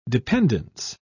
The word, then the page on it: dependance: British English